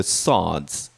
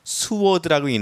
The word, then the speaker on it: sword: They sheathed the swords. 그들은 칼집에 칼을 넣었다. 네, 칼은 뭐라고 그러죠? 자, 이것을 칼이라고 그랬는데 칼은 sword라고 읽는 게 아니라 sword라고 읽는 거예요. 왜냐하면 W가 묵음이에요.